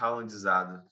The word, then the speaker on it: Haalandizado: Você está ralandizado? Caramba, hoje minha, minha pronúncia tá, tá ótima. Ralandizado? Ralandizado. – Caramba, eu tô com a língua presa hoje. Mas, enfim, eu tô totalmente Haalandizado.